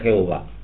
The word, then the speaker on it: Jehová: y la palabra Yod G, Vav G se pronuncia Jehová.